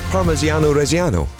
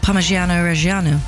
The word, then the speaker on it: Parmigiano Reggiano: It's Parmigiano Reggiano. – Parmigiano Reggiano.